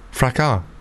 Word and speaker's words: fracas: We got the way to pronounce it. I was right. – Fracas.